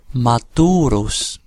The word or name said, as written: maturus